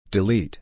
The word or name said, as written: delete